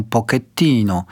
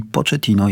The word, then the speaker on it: Pochettino: Napisał Michał, cześć, uczę się włoskiego i zabolała mnie wymowa Pochettino, bo Włosi wymawiają Pochettino. Pozdrawiam. Michale, otóż nazwisko Mauricio Pochettino rzeczywiście jest nazwiskiem pochodzenia włoskiego, niemniej Mauricio jest Argentyńczykiem i nazwisko tutaj czytamy z hiszpańska, czyli Pochettino jest właściwą wersją.